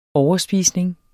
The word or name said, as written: overspisning